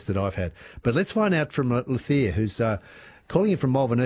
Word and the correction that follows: Lothar: Did I get your pronunciation right? – Now, my name is Lothar. – Lothar. Yep. Lovely to have you with us, Lothar.